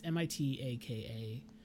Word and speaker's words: Mitaka: Yeah, and then I believe that you pronounce the new protagonist's name is Mitaki, I think it's how it's pronounced. It's Mitaka.